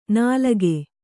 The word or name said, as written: ನಾಲಗೆ